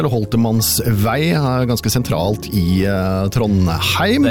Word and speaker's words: Trondheim: I Trondheim sier vi Trondheim. – Trondheim.